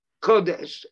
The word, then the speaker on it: kodesh: Now, kodesh is not written with a vav. It's got a cholem, which makes the same vowel sound, but it's just pronounced kodesh, and it is, it's not written with a vav.